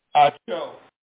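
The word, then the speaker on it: Ahio: And Uzzah and Aho, let me tell you pronounce this name. Where is it? Right here. Achio.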